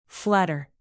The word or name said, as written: flatter